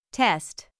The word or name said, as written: test